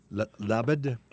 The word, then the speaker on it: Laubade: And so the first one is, this is the Chateau Labade, L-A-U-B-A-D-E. Laubade is what you might think, but it's not.